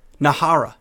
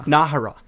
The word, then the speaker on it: Nájera: Nájera. – Nájera.